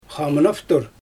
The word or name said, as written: xamynaftur